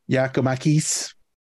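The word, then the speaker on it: Giakoumakis: I'm going to go with Giacomanis. Giacomakis. That's not what the pronunciation guide says. It says Giacomakis.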